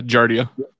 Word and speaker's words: Giardia: Or how do you pronounce it? Giardia? – Yeah, Giardia.